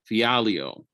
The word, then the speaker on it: Fialho: Fialho.